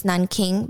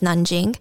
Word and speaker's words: Nanjing: Um, well, I guess the, the English term for that city is Nanking, but I believe the Chinese pronunciation is Nanjing. So I'm just going to go with Nanjing.